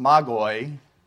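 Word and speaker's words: magoi (μάγοι): So the Greek word would be pronounced magoi, and then you can see how that's translated magi and wise men.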